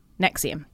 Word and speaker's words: NXIVM: Now, it's spelt N-X-I-V-M, but it's pronounced Nexium.